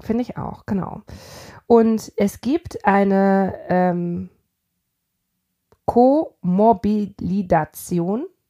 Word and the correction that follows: Komorbiditäten: Ich sag dieses Wort nicht mehr, Leute. Ich kann dieses Wort nicht aussprechen. – Co-was? – Komorbiditäten. Also das etwas Komorbiditäten.